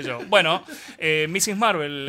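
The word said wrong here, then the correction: Ms. Marvel: Miss Marvel.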